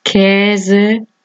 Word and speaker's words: Käse: A con diéresis se pronuncia como E abierta. Hässlich, Gepäck, Käse, Spät.